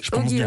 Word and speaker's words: Oggy: Vous êtes scénariste également, scénariste de dessins animés, la série Oggy, par exemple. C'est comme ça qu'on prononce. – Oui, Oggy. Ouais.